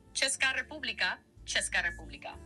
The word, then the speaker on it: Česká: Y se pronuncia aproximadamente Česká República. Detalles. Česká. Che suena como che de chico.